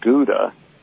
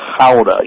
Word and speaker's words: Gouda: And it's not Gouda in the Netherlands. In the Dutch, they call it Gouda. You got to kind of grunt a little bit as you say it. So that's the correct pronunciation of that cheese.